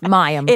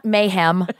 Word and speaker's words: Mayim: I got to know that I've been saying her name wrong for 20 years. It's Mayam. It is Mayam. It's not Mayam. It's not Mayhem. It's Mayam.